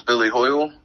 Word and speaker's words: Billy Hoyle: My name is Billy Hoyle.